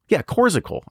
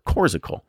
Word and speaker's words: Coorsicle: So Coors Icicle is intended for, Coorsicle. I was saying it wrong. It's Coors hyphen ICLE. So Coorsicle.